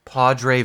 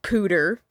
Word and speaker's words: Poudre: Okay, so I just Googled it, and apparently it's literally pronounced pooter.